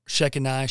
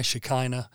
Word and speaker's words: Shekinah: Shekinah, Shekinah. I've heard people pronounce that both ways, but it's the female aspect of God.